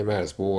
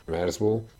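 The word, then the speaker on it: Merzbow: Merzbo 1979 óta több száz felvételt jelentetett meg. A projekt neve Cool Schwitzer Merzbo című alkotásából ered, és a kiejtése is megegyezik azzal.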